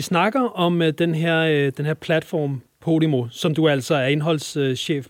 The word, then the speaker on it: Podimo: Jeg ved ikke, om det har været den største udfordring, men en af udfordringerne har været, hvordan vores navn blev udtalt, og det udtales altså Podimo.